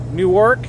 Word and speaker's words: Newark: How do you say that anyway? Newark? Newark? Not New York. N-E-W-A-R-K.